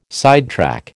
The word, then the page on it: sidetrack: American English